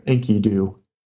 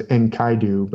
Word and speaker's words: Enkidu: We don't know exactly how they pronounce their words, but usually the scholars that I've listened to call him Enkidu. I always thought it was Enkaidu.